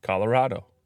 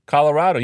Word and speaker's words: Colorado: You say Colorado, Colorado. Turns out you moved to Colorado. Nobody in Colorado pronounces it Colorado. You know what they say? Colorado.